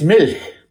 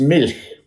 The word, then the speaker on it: Milch: If people get pronounced, oh yeah, I've got to tell you, it's Milch. It's Milch. – Milch. – Milch. Just milch. Like you think it would be. Not Milch, but Milch, I believe. But also, Milch.